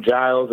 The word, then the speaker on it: Giles: Sometimes people misspell my name Giles and they spell it with a J, but it's actually with a G.